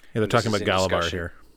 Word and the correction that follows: Gavilar: Or Gavilar. I keep saying that wrong. – Gavilar, yeah, King Gavilar.